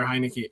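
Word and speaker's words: Heinicke: I mean, how do you pronounce this guy's name? – Right, Taylor lives just down the road from me. It's Taylor Heineke.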